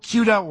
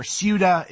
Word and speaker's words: Ceuta: So Ceuta, or Ceuta, is, I've heard it pronounced both ways, is a part of Spain that is actually on the northwesternmost part of Africa.